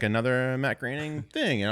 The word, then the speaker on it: Groening: This is Matt Groening, which is how you pronounce his last name, not groaning like I've been doing for the first 32 years of my life, 33 years of my life.